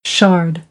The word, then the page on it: shard: American English; ɑːr